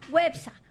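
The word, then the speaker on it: Güepsa: ¿Cómo se dice a los que nacieron en Huespa? Perdón, Huepsa. ¿Ah? Con diéresis. – Huepsa.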